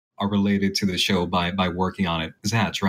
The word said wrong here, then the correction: Zach: right? Or Zach? – No, Zach and Tara. Just pronounced it Zatch because he's stupid and illiterate.